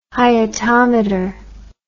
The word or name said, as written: hyetometer